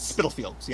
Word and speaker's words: Spitalfields: The relationship might have been uh, abusive, and after that, she ended up at a lodging house in how did you pronounce it earlier? Spitalfields. Spitalfields, yeah. Okay, I, I my brain wanted to go Spitalfields, so I'm, I'm going to go with yours.